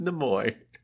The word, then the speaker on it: Nimoy: I have a third-hand account that it is pronounced Nimoy.